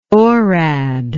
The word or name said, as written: orad